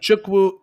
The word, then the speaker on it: Ikechukwu: Ikechukwu, Ikechukwu Azotam. kind of like the guy that plays uh, the High Evolutionary, Chukwudi.